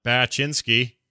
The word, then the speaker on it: Baczynski: Lana Baczynski. Am I saying it right? – Yeah, I think it's okay. – Right, close enough. I didn't sure. think that, like, I knew. Yeah, you got it.